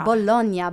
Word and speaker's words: Bologna: Bologna, bella. – Oh, sorry, Bologna. They've gone to Bologna. – Still saying it wrong.